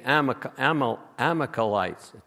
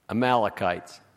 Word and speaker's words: Amalekites: And Saul was supposed to kill all the Amalekites. I didn't pronounce that right. Amalekites. Thank you.